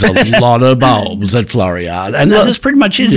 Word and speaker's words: Floriade: Floriade.